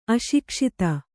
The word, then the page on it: ಅಶಿಕ್ಷಿತ: Kannada